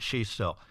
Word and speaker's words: Xisto: Now, Daniel, for the sake of our listeners joining us, you have contributed articles and issues to research, and the spelling of your last name, because they may want to look those up, is X-I-S-T-O. We pronounce it Shisto, X-I-S-T-O.